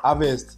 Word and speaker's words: Havertz: Havertz.